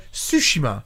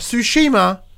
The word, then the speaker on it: Tsushima: As I said at the start of the show, Tsushima. Not Tsushima.